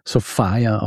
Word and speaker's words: Sophia: Geschrieben Sophia, aber entsprechend der damaligen Mode auch wird sie tatsächlich Sophia ausgesprochen.